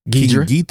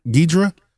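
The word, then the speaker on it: Ghidorah: King how do you pronounce it? – Ghidra. – Ghidra.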